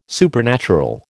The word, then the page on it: supernatural: American English